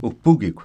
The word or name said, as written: wpukikw